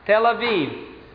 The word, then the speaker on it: Tel Aviv: Tel Aviv.